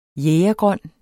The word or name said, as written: jægergrøn